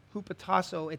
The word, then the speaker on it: hypotasso: And if you look it up, the Greek word for submit is hupotasso. Hupotasso. It's actually spelled, though, H-Y-P-O-T-A-S-S-O. It's pronounced hupotasso.